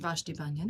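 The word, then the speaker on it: Vashti Bunyan: And Vashti Banyan.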